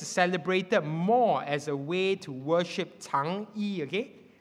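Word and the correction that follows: Chang'e: Which is Chang E, yeah. I saw Pastor Roger doing this to me, telling me that I've mispronounced wrongly. Okay. Chang E, okay?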